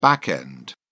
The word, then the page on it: back end: British English